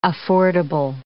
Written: affordable

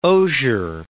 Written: osier

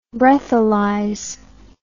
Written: breathalyze

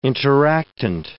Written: interactant

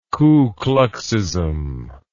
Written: ku kluxism

